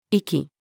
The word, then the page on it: 粋: female